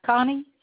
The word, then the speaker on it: Connie: Connie, C-O-N-N-I-E.